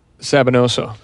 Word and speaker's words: Sabinoso: Am I saying it right? – Sabinosa. – Yeah.